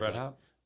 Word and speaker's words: Breithaupt: Like when you look at things like Scott Breithaupt, I hope I'm pronouncing that right, that, you know, is considered Breithaupt. – Is that how you say it? Breithaupt? – Yeah.